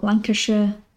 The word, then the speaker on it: Lancashire: The other one is S-H-I-R-E. What people would pronounce as Shire is actually a sh. So Lancashire, Gloucestershire, Hampshire. Again, I don't know why we pronounce it like that, but it's a thing that's universal across the UK, pretty much.